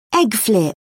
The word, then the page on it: egg flip: British English